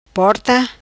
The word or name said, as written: Porta